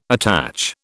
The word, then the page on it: attach: British English